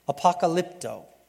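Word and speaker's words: Apocalypto (ἀποκαλύπτω): The word he's using here for revealed is a simple word that I can't say unless Bible Gateway told me how to say it. Apocalypto. That's it.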